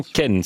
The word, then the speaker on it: Kennes: C'est paru aux éditions Kennes. D'accord, c'est paru aux éditions Kennes. – Ça marche pas mal. – C'est la différence entre la prononciation à la française ou à la belge.